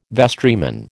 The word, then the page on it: vestryman: American English